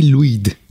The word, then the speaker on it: Lwyd: del otro lado del charco francés, los galeses tienen su propio espectro navideño en la forma de Marie louis que se escribe L-W-Y-D. Ya ves que los galeses, su idioma está bien pinche raro. No tiene vocales esa palabra, güey.